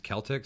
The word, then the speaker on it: Celtx: I used to use one called, I think it's pronounced Celtic, C-E-L-T-X.